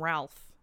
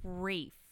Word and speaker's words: Ralph: His name is spelled like that, but I think I remember reading somewhere that it's not pronounced that way. – Do people call him that? Because see, I always get confused about that. So it's Ray. – It's like Rafe.